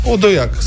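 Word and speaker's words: Odojak: Așa scrie Odojac cu capa. Odoiac se pronunță.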